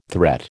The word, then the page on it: threat: American English